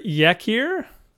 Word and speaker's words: Yakir: It was written by Leonard Yakir. I'm guessing that's why my voice went up at the end there. – That's how he pronounces it, too. – Yeah, probably. – Yakir? – Always is it.